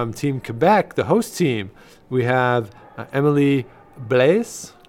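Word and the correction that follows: Blais: Blay. Blay. – Blay. Blay. – Uh, I, I, I know. I, my French uh, pronunciation isn't the strongest.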